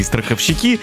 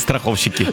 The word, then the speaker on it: страховщики: Мы поздравляем всех, причем страховщики говорят, что они страховщики, а правильно говорить страховщики.